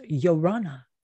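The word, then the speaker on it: Ia ora na: It's spelled with an I, I-A-O-R-A-N-A, but it's pronounced Yorana.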